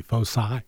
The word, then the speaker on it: foci: It's a soft C, foci.